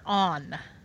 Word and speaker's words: Anne: So the interesting thing is they're actually sticking with the Japanese pronunciation for her name, so they're calling her On. – It's not a pan. – That's weird. – How's it spelled? – A-N-N-E.